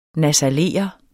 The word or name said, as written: nasalere